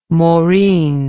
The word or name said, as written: maureen